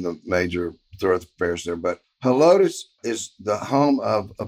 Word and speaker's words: Helotes: In Helotes, Texas.